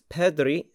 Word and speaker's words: perdrix: Now, in French, partridge are called perdrix. Excellent pronunciation there. That's perdrix, spelt P-E-R-D-R-I-X.